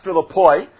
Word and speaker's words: Philippi: It's actually pronounced Philippoi, but it's spelled as Philippi, and that's how we typically pronounce it.